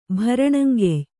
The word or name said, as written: ಭರಣಂಗೆಯ್